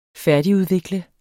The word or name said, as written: færdigudvikle